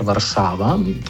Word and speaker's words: Varšava: Namreč po prvopisu iz leta 2001 Ukrajina je ustrezen le izgovor Varšava, tako kot ste vi dosledno izgovarjali, in tem, ko sem jaz mislim, da izgovoril Varšava.